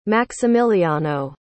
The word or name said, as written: maximiliano